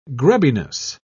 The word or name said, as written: grubbiness